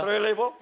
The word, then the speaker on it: Trelleborg: That lady couldn't push the Trelleborg. She couldn't push it. – Denne damen, hun klarte ikke dytte Trelleborg. – Am I saying it right? Is it okay? – Sier jeg det rett? Ja. – Trelleborg? – Trelleborg.